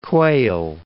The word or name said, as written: Quayle